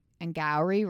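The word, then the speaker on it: Angourie: I don't 100% know how to say her name. I've listened to it 400 times before. And then I put it phonetically. She is Australian, but it's Angowri Rice.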